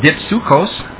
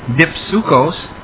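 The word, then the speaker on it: dipsuchos: It's a G1374, pronounced dipsukos, dipsukos.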